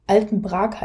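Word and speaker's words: Altenbrak: Also wenn du diesen Sommer fährst, mh, hm, dann kannst du lieber in die Alt Waldbühne Altenbrag gehen. Dort werden die Sommerfestspiele stattfinden. Ähm, auch Altenbrag heißt es, richtig ausgesprochen.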